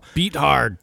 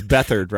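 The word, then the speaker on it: Beathard: Beat hard. – Dude. – Is that how you pronounce it? – No, it's Bethard, right? – Yeah, Bethard.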